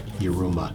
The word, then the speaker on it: Yiruma: Well, actually, there's a Korean artist, Ymira. – Ymira? – Yeah. Or no, Yiruma. That's it. – Yiruma. – But they pronounce it Iruma. But it's Y. Yiruma.